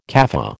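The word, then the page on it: café: American English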